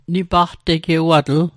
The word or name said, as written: nipaqtegewatl